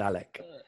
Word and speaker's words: Alex: I do kind of disagree because his name is Alex. – It's got an X in the end. – Yes, but it's pronounced Alec.